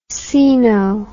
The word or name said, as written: coeno